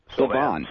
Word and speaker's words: Sylvain: Well, according to the Peoria Journal Star last week, WWE SmackDown star Sylvian. Am I pronouncing that right? – Sylvain. Sly.